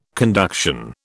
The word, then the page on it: conduction: British English